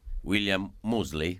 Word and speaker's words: William Moseley: Sentiamoci un pezzetto di Le Cronache di Narnia, per esempio, dove tu doppiavi William Moseley.